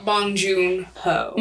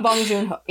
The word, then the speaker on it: Bong Joon-ho: I'll talk about Bong Joon-ho. – Mm-hmm. – I didn't mean to say his name like that. It was just the way it came out. – Bong Joon-ho, yeah.